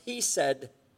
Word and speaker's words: hesed: Now that phrase, faithful love there, that's a translation of a Hebrew word pronounced hesed, H-E-S-E-D, and it refers to God's loyal affection for those who are under his covenant, his children.